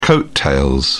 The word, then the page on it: coat tails: British English